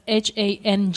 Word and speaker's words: Hanh: It's written Hanh, but it's actually pronounced as Hang.